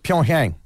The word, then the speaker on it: Pyeongchang: Pyeongchang.